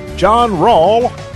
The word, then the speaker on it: John Rawl: John Rawl, rhymes with y'all, is my name.